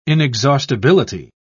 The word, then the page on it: inexhaustibility: British English